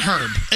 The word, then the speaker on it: herb: I call it herb. I know it's herb, but I call it herb because I see the H and I pronounce it.